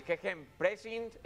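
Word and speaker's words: Gegenpressing: El equipo de Ragnik llega con su arma secreta, el GG Press. Bueno, pues ese sistema que el GG Pressing, ahora sí, pronunciado bien,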